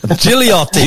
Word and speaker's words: Gigliotti: Did I say your last name right there, Simon? – Well, it was a good attempt. Last name, Gigliotti. – Gigliotti. – Gigliotti, got it.